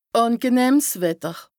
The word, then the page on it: àngenëhms Wetter: Bas-Rhin; Strasbourg